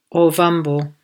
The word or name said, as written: Ovambo